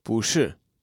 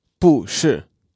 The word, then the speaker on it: 不是: Pu is in the fourth tone, but when it is followed by a syllable in a fourth tone, it changes to second tone. So, not to be is 不是 instead of 不是.